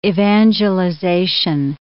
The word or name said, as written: evangelization